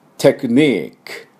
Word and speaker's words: technique: E は発音しません。 TECHNIQUE ですね。テクニック。